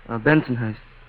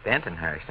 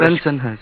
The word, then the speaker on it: Bensonhurst: Bensonhurst. – Bentonhurst. Now you go Bensonhurst.